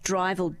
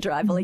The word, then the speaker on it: Drivl: So we came up with the word Drivel, which is D-R-I-V-L, which could be interpreted in many different ways.